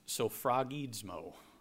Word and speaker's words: sphragizo: And this Greek word is hard to pronounce. It's sophragizmo. Sophragizmo. S-P-H-R-A-G-I-Z-O.